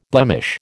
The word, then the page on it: blemish: American English